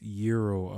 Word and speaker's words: gyro: I think, yeah, even, but the, like, gyro or gyro. – Yeah, it's gyro, apparently. – Oh, it's pronounced gyro. – Yo, trust me, I got into these conversations before. When I called it gyro, and someone laughed at me once. So you gotta make sure that you say it right.